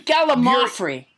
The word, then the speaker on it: gallimaufry: It's actually Gallimaufry. That's what it is. Gallimaufry. I've been saying it wrong.